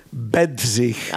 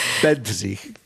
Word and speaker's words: Bedřich: Bedřich. – Ah, danke. – Bedřich.